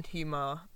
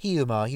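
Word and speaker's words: Hiiumaa: I mean, basically, even on Hjumar, I can't say this island. – Hjumar, you got it right.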